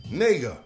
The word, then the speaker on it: Knigga: And they have a name called K-N-I-G-G-A. Noah Kanega. Nega is how it's pronounced.